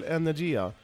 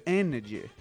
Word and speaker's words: Energia: Du sa ju energy. Det är typ som att radio står... – Energia, det är ju samma sak som energy. Ja, men du kollar här, du står ju helt olika. – Ja, ja, men det är väl klart som fan att när du uttalar men, annorlunda. – Det är klart, det är ju ryskt uttal.